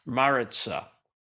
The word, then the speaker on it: Maritza: I would say the most accessible is Countess Maritza, or Maritza, as Americans pronounce it, Maritza, M-A-R-I-Z-I-T-A. Is it I-Z? Maritza, M-A-R-I-T-Z-A, M-A-R-I-T-Z-A, Maritza, Maritza. You may recognize,